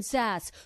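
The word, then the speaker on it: Sasse: Is it Sass or Sassy? – It's Sass. – I was going to say Sassy, the way it's spelled.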